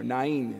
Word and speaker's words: Nain: Now, we heard in the gospel passage, this very short passage, about the widow of Nain, or Nain as it's pronounced.